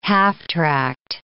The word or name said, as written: half-tracked